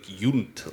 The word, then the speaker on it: Yount: it's Germanic, and it really is like J-U-N-D-T, like Yount. Like. – Oh, geez. – So it really is actually closer to being pronounced like that.